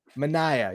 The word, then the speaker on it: Manaea: Manaea.